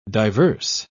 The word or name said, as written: diverse